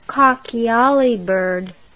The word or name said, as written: cockyollybird